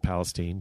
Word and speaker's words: Palestine: I was born in a town called Palestine, Texas, which is, like, Palestine, but they pronounce it Palestine just right.